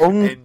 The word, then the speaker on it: Ng: And his surname is spelled N. – Piss off. It's not called it's Ung. I'll tell you what, it's Ung that, because we had a teacher at our school called Mr. Ung, and his name was Mr. NG.